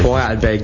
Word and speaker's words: Vorarlberg: Vorarlberg, so spricht man. Vorarlberg, genau.